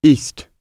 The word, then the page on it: east: British English